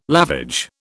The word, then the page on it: lavage: British English